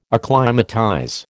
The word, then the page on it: acclimatize: American English